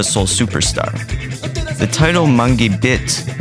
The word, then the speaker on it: Bit: The name Mangue Beat, B-E-A-T, comes from the song Mangue Bit, B-I-T, by Mundo Livre S.A.A., one of the two original Mangue groups with Chico Science and Nassau Zumbi. In Portuguese, bit is pronounced like beat, and journalists started to name the movement after the song.